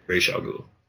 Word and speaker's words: Ra's al Ghul: Ra's al Ghul. – Ra's al Ghul, yeah. – That's how it's supposed to be said, but I think they said it wrong in the movie the whole time.